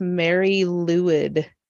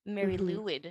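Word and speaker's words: Mari Lwyd: I just looked up how to say it. It's Mary Lewid. – Mary Lewid.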